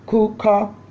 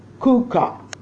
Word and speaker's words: Chukat: It's called Kuka, and it's spelled C-H-U-K-A-T, Kuka, and it means the ordinance of.